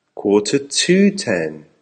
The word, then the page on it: quarter to 10: American English